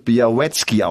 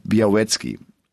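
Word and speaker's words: Białecki: Aber auch andere sagen, nein, der wird Biawacki ausgesprochen. Also das L wohl nicht, sondern wie so ein W. Und dann halt Biawacki.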